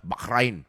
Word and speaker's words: Bahrain: It's always pronounced as Bahrain.